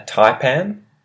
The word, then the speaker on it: taipan: A taipan. Taipan. And taipan is spelled T-A-I-P-A-N. Taipan.